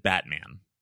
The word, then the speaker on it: Batman: So one of the presenters in this webinar, his name is David Batman. – What? – His last name is spelled like the superhero and pronounced like the superhero.